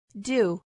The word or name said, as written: do